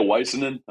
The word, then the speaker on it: Waisanen: I say a Waisanen. I actually met, there's a Finnish guy on Phylabs. It's a Finnish last name.